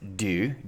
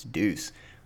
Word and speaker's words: Deux: That was Let's Do the Time Warp Again, The Verge Hack Part 2, which I learned is actually pronounced Deux, D-E-U-X. I've always referred to it as Deuce.